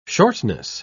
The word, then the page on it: shortness: British English